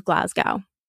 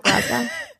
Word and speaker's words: Glasgow: It's actually a little bit more than the city of Glasgow. – Wow. Two things. That's a lot. And the way you say Glasgow, brilliant. – Wait, is it not Glasgow? How do you say it? – Glasgow.